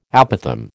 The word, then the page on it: apothem: American English